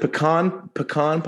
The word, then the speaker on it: pecan: Is it pecan pie? Am I pecan? Am I saying it wrong? Am I offending every Southerner? – Pecan is the correct way to say it.